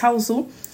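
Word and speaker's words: Hausu: Então, Haozu, né, também conhecido pela essa pronúncia com o toque japonês Haozu,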